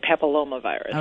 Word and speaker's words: papillomavirus: I've noticed a lot of my friends and acquaintances on Facebook are posting something about this HPV vaccination, which is it human papilloma? I don't even know how to say it. What's HPV? – Human papillomavirus. – I was close.